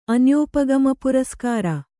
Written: ಅನ್ಯೋಪಗಮಪುರಸ್ಕಾರ

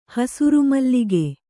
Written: ಹಸುರುಮಲ್ಲಿಗೆ

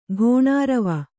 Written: ಘೋಣಾರವ

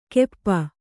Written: ಕೆಪ್ಪ

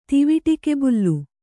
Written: ತಿವಿಟಿಕೆಬುಲ್ಲು

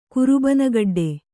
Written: ಕುರುಬನಗಡ್ಡೆ

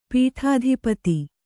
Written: ಪೀಠಾಧಿಪತಿ

